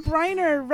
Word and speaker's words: Briner: Justin Briner rhymes with whiner? – Yeah, I put Justin as my first pick.